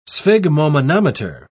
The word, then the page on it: sphygmomanometer: British English